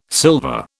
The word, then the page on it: silver: British English